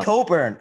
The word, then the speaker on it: Cockburn: It's like Kofi Coburn. It's spelled Cockburn, but it's Coburn.